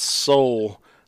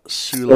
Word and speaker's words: Soule: Night of Ren said it's pronounced Soul, not Soule.